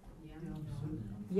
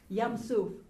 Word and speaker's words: Yam suf: Kamatz to patah. Long a sound to a short a sound. Let's read this. – Yam su.